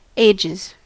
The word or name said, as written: paye